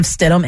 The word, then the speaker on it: Stidham: Stidham.